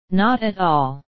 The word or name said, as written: not at all